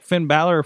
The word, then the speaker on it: Finn Balor: They brought out like Finn Balor from. I said it right. Did I say it right? – Yes. Yeah.